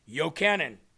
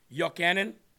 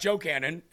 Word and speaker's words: Yochanan: Jeremy, the J in Hebrew is really pronounced like a Y. Yochanan. Yeah, isn't that how I pronounced it? Yochanan. John, Yochanan. It's not Joe Cannon, right?